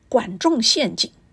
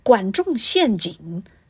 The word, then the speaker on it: 管仲陷阱: In Mandarin Chinese, it sounds Guan Zhong Xian Jing. Well, the inability to break out of the dynastic cycle can be described as 难以自拔. Put together, it can be pronounced Guan Zhong Xian Jing, 难以自拔.Please follow me to say twice again.